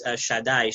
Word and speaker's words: Shaddai: The name Shakai, it's actually pronounced Shaddai, Shin Dalet Yud.